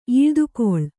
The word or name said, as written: ಈಳ್ದುಕೊಳ್